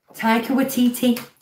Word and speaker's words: Taika Waititi: So, Taika Waititi. Did I say it right? Do I get a ding? – I think so.